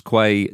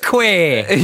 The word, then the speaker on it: Quay: Forgive pronunciation, but I'm pretty sure it's Connors Quay Nomads. – It's definitely not Quay, is it? – Connors Quay. – You know that word.